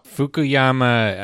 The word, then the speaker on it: Fukuyama: Both Fukuyama and am I even pronouncing his name rightly? Fukuyama? I don't know. – Fukuyama, I think, is correct.